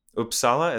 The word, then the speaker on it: Uppsala: And from him and a few of my other Swedish friends, I've heard of Uppsala.